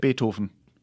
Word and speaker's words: Beethoven: How do you pronounce... Beethoven in German. If you were in Germany, how would you pronounce it? – Beethoven. – Really? Oh. Is that? – Yeah, we do it like this.